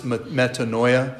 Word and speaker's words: metanoia: You've seen it, probably heard or written and pronounced as metanoia. It's pronounced metanoia in Greek.